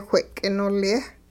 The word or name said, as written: whe'k'inolye